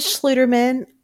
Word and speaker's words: Schluterman: My last name is Schluterman. I tell people it's like shoe, but with an L in there.